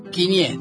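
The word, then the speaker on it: kiñe: Uno. Quinié, quinié, ahí está, quinié.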